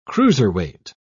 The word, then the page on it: cruiserweight: British English